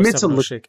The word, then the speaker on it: 070 Shake: Is it 70 Shake? Is that how we pronounce this? – I don't know if it's 070 Shake or if it's, oh man. – It's gotta be 070 Shake.